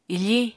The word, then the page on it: ifiñ: apatani